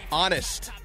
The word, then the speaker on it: honest: Text the word honest. I like that. Honest. Honest Abe. Text the word honest to 72881 and you are registered. Text honest. H-O-N-E-S-T. Be mindful of the silent H. H-O-N-E-S-T.